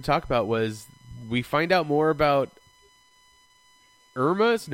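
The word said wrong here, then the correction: Imra: no, how do you say her name? Saturn Girl? – Emra, thank you.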